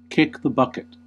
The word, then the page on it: kick the bucket: /ˈkɪk ðə ˈbʌ.kɪt/